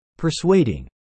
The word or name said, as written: persuading